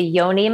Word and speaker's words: yoni: Another thing I saw on your website, by the way, which was very intriguing, was the, and I might be pronouncing it wrong, but the yoni massage. – Yes. – Is that how you pronounce it? Yes, that's one of the services that I offer.